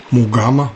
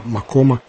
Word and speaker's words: макам: Теснив – это романс, который, как правило, исполняется до или после большого сочинения «Мугама», «Макома» или «Макама» в разных произношениях.